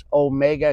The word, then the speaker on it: Omega: It's Omega because it's a long O sound, there's another letter called an Omicron, or O minor, which is a short O sound.